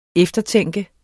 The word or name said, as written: eftertænke